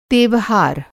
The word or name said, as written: तेवहार